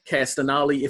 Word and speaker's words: Castagnoli: Claudio Castagnoli, if I'm pronouncing that last yep. name correctly. Yep.